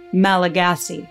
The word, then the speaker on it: Malagasy: A brief side note the native language on Madagascar is spelled Malagasy phonetically, but pronounced, to the best of my ability and understanding, Malagas.